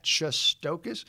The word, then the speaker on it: Shestokas: I tweet at Shestokas.